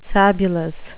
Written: sabulous

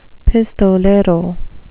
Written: pistolero

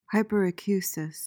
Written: hyperacusis

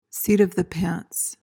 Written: seat-of-the-pants

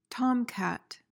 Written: tomcat

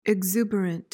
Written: exuberant

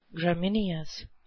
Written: gramineous

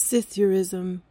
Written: psithurism

